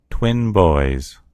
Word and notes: twin boys: American English

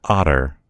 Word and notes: otter: American English

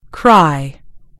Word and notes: cry: British English

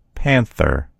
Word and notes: panther: American English

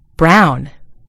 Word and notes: brown: British English

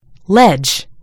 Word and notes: ledge: American English

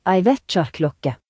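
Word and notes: alarm clock: Norwegian Nynorsk

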